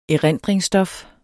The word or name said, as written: erindringsstof